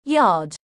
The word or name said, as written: Yard